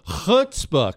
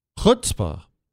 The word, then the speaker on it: chutzpah: How do you say it? You say it for me. Chutzpah. Back of the throat? Chutzpah.